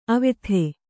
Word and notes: ಅವ್ಯಥೆ: avyathe

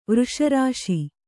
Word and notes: ವೃಷರಾಶಿ: Kannada

